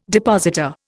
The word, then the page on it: depositor: Indian